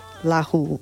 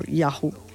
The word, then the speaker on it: laxoox: And I had to find how to pronounce that name because it is written L-A-X-O-O-X. But it is pronounced lahu or yahu.